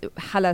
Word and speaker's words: Hala: I mean, I was literally, I literally went by the name Holly, first grade until like eighth grade, seventh grade, because hala is such a hard thing for this that strong h sound doesn't exist in English, and people would always mispronounce it and all that.